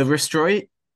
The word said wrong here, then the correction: Roystonian: How do you say it again? Restroy? – Roystonian. – Roystonian.